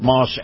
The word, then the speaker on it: Maus: Moss Acura. Now, Moss is spelled M-A-U-S, and Acura is a beautiful, new, redesigned SUV that you're going to love to drive.